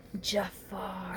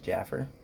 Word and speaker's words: Jafar: Jafar. – Excuse me, it's pronounced Jaffer.